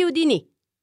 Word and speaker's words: Houdini: Da quel giorno si chiamarono i fratelli Houdini, con l'accento sulla I, alla francese.